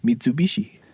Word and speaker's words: Mitsubishi: Mitsubishi. You said it right. Good job. – Oh, great news. – I don't know. I don't know anyone that says that one. – I know they don't, because they actually say it right. But Mitsu is three, Bishi is diamond.